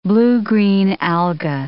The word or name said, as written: blue-green alga